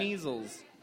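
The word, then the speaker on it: Kneazles: Kneezles. Kneezles or kneezles, however you say it. It's a silent K.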